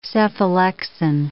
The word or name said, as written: cephalexin